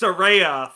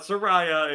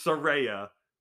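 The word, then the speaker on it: Saraya: Oh, okay, Soraya. And then on commentary. All right. Hello, everyone. Just want to say it's Soraya, first of all. Not Soraya. It's Soraya.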